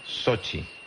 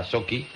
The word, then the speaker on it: Sochi: Dice: hola Javier, mi mujer es rusa y se pronuncia Sochi. Bueno, pues yo decía Sochi, porque mucha gente también lo dice, y bueno, hay en la duda entre Sochi y Sochi.